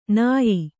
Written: ನಯ

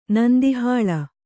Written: ನಂದಿಹಾಳ

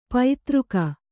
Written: ಪೈತೃಕ

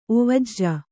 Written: ಒವಜ

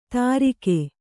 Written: ದಾರಿಕೆ

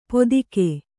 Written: ಪೊತ್ತಿಗೆ

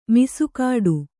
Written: ಮೀಸೆಗಡಿ

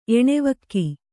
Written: ಎಣೆವಕ್ಕಿ

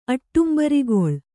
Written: ಅಟ್ಟುಂಬರಿಗೊಳ್

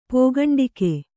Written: ಪೋಗಂಡಿಕೆ

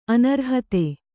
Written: ಅನರ್ಹತೆ

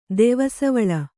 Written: ದೆವಸವಳ

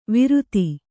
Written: ವಿರುತಿ

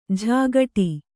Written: ಝಾಗಟಿ

